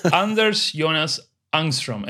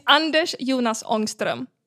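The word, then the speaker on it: Anders Jonas Ångström: So, Anders Jonas Angstrom. Am I saying it correctly? – Not really, but that's okay. It's Anders Jonas Angstrom.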